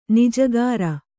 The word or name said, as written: ಞಕಾರ